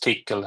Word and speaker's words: Tcl: But it should be pronounced Tickle, I think.